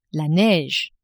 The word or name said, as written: neige